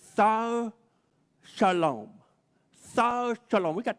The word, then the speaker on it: czar: The Romans, they used that word Sar, and they pronounced, well, they spell it differently, C-Z-A-R, Sar, and they used that word, and then eventually that word became the word Caesar, like Julius Caesar.